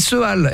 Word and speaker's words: Seal: Et ben, Seal. C'est comme ça qu'on dit, hein, Seal. – Ouais, ça s'écrit Seal, S-E-A-L-E. S-E-A-L, pardon.